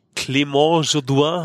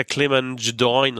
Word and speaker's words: Clément Jodoin: Und das ist ja, weil er halt Franco-Kanadier ist, es ist halt natürlich die französische Aussprache. – Okay, sonst wäre es ja Clément Jodoin oder so wahrscheinlich.